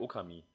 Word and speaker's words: Okami: war ja Okami. Beziehungsweise Okami spricht man es ja aus.